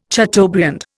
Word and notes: chateaubriand: Indian

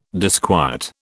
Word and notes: disquiet: British English

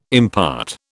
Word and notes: impart: British English

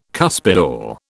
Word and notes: cuspidor: British English

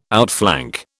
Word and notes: outflank: British English

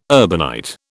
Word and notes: urbanite: British English

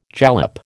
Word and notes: jalap: American English

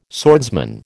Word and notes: swordsman: American English

